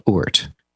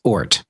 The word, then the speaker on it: Oort: Oort, and it is spelled Oort. So just like the Oort cloud. – So it's not like Mork from Mork, it's Oort. – It's Oort.